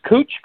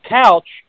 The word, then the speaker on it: Cooch: Who's this Cooch guy?" And I'm like, "The Cooch guy's name, it's pronounced Couch, and I'm pretty sure he ran you over the last game."